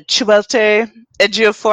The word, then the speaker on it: Chiwetel Ejiofor: um, starring Charlize Theron and uh, Chiwetel Ejiofor.